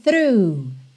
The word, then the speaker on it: through: Lembrando a pronúncia dessa palavra, T-H-R-O-U-G-H. O GH do final você ignora, não vai ter som nenhum.